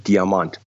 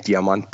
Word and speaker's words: diamant: Well, you would call it diamond, but in Germany we pronounce it diamant. A brand new shiny silver metallic diamant bike, brand new item, one gear of course only.